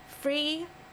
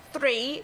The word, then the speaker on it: Free: Free Peace Sweet. I laugh because I have to try and say that right. It's not three, it's free.